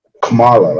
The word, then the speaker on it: Kamala: And dude was like, oh, no, it's like, Kamala, like Kamala, that's how you pronounce her name.